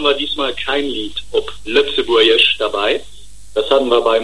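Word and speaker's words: Lëtzebuergesch: Also vor allem spricht man mal Lützeburgisch.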